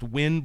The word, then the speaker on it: Nguyen: It's like pronounced win, but it's NGUYEN.